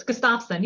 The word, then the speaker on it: Gustafson: It's Gustafson, yes.